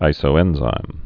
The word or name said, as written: isoenzymes'